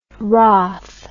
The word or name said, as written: rath